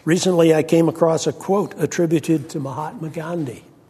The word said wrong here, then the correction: Mahatma Gandhi: I didn't say that right. Mahatma Gandhi. Got an extra syllable in there.